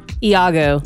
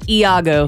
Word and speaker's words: Iago: Is it Lago or Lago? – Iago. – Lago? Okay. – No, Iago. – Iago. Pardon me. I'm really sorry I mispronounced that.